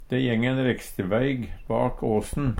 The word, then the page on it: reksteveig: Numedalsmål